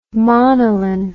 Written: monellin

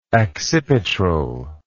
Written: accipitral

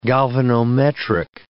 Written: galvanometric

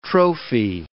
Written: trophy

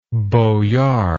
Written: boyardism

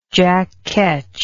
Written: jackketch

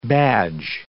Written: badge

